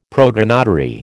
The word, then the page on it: protonotary: American English